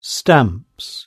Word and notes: stamps: British English

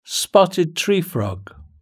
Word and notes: spotted tree frog: British English

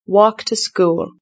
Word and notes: walk to school: British English

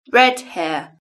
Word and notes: red hair: British English

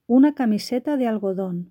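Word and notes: una camiseta de algodon: Spanish (Spain)

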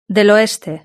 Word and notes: del oeste: Spain

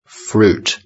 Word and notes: fruit: British English

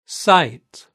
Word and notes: site: British English

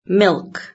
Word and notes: milk: British English

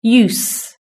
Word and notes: use: British English

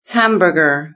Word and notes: hamburger: British English

